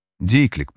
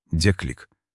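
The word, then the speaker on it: déclic: Дейклик произносится. Деклик.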